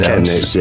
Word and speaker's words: Chemnitz: But for some reason, the chur in Chemnitz is pronounced Chemnitz, yeah. – Like a K.